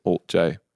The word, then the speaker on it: alt-J: We were like, we're going to call ourselves, our band name will be the symbol, but you'll pronounce it Alt J.